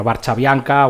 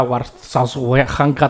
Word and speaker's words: Warszawianka: Y por si había duda, el himno, el título de la versión original es la Varsoviana, la Varchavianka, Warszawianka, tú que sabrías pronunciarlo bien. – No tengo ni idea, Varsavianka o algo así, igual. – Yo creo que lo de Varchavianka es la transcripción fonética a los castellano parlantes.